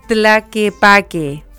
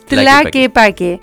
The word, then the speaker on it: Tlaquepaque: Tequila me encantó y a mí me encantó Tlaquepaque. Por fin lo dije bien. ¿Viste que lo dije bien? Tlaquepaque.